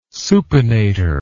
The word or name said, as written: supinator